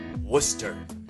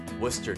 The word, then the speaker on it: Worcester: Brent Newberry is the pastor of the First Baptist Church in Worcester, Massachusetts. Worcester. Worcester. Not to be confused with Worcester, Ohio, where they spell it phonetically.